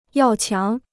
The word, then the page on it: 要强: yào qiáng